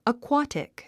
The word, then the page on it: aquatic: əkwǽtik; アクワティク